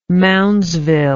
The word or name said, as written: moundsville